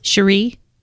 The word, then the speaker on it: Cherie: Cherie. – Is it Cherie? – You got it right. – Oh, okay. I was going to say Cherry. I'm sorry. But Cherie.